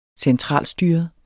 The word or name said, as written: centralstyret